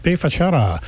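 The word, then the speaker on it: B Fachada: Before that, Bay Fachada, which looks like B. That's how they pronounce it in Portuguese.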